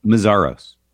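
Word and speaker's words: Meszaros: How do you say his last name? – Mazaros.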